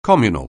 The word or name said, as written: communal